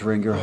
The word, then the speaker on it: Rangiora: Yeah, Rangiora.